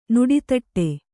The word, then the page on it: ನುಡಿತಟ್ಟೆ: nuḍl taṭṭe